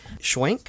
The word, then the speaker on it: Schwenk: It's a lot of fun, and we have some special guests with us today, Mr. Greg Schwenk.